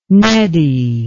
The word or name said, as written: neddy